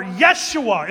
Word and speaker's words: Yeshua: Or Yeshua, as it's more properly pronounced.